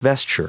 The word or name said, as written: vesture